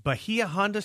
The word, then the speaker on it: Bahia Honda: So at mile marker 37 in the Florida Keys is Bahia Honda State Park. Am I saying that correctly? – You absolutely are, and you're right.